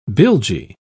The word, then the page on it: bilgy: British English